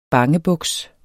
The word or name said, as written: bangebuks